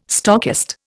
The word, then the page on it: stockist: Indian